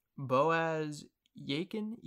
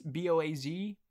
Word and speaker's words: Boaz Yakin: Boaz Yakin. It's Boazykin. Boaz Yakin.